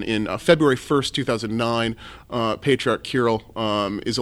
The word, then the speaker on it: Kirill: I came across pronounce the name again for me. – Oh, Patriarch Kirill. – Kirill. Yeah. Kirill. Kirill. – American pronunciation, we're fine either way.